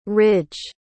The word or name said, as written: rydge